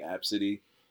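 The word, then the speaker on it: Abcde: It's spelled ABCD, but it's pronounced like Absidy.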